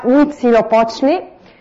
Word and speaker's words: Huitzilopochtli: Per gli aztechi, questo me lo sono dovuto scrivere, credo che possiate perdonarmi, si pronuncia Huitzilopochtli, e nonostante questa tenuta da colibrì, è anche lui il dio della guerra e del sacrificio umano.